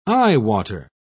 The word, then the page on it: eyewater: British English